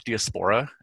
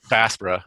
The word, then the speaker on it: Diaspora: Because I feel like there's so much room to talk about like Black Diaspora and that. I don't pronounce that right, because I always read it. – Diaspora.